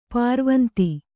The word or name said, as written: ಪುರವಂತ